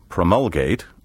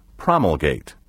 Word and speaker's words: promulgate: You may pronounce this word either promulgate or promulgate, as you please.